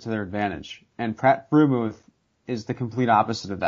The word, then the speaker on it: Freiermuth: Pat Freiermuth.